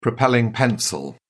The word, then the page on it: propelling pencil: British English